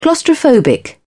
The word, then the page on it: claustrophobic: British English